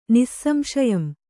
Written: ನಿಸ್ಸಂಶಯಂ

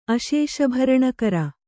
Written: ಅಶೇಷಭರಣಕರ

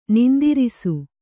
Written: ನಿಂದಿರಿಸು

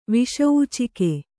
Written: ವಿಷೂಚಿಕೆ

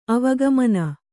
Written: ಆವುಗೆಮನೆ

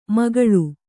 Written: ಮೇಖಳೆ

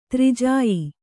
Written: ದುರ್ಜೇಯ

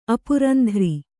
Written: ಅಪುರಂಧ್ರಿ

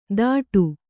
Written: ಥಾಟು

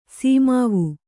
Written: ಸೀಮಾವು

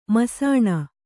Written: ಮುಸುಣಿ

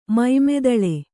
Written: ಮೈಮೆದಳೆ

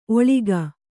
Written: ಒಳ್ಗು

